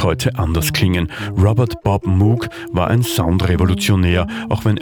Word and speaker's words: Moog: Robert Moog, der eigentlich Moog ausgesprochen wird.